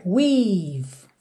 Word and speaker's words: weave: Aqui, muita atenção a essas letrinhas E que estão pintadas de cinza, porque você realmente não pronuncia. Percebe que é weave. Você fecha o som no V.